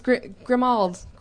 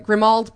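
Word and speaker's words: Grimmauld: oh, it's Grimald. Grimald, that's how you say it in American, Grim Old Place. And somebody, British, came up to us. I think it was Jamie, who said, no, it's Grimold. Get it, Grimold.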